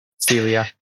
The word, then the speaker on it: Cylia: Imagine the Y being an E. – Cylia.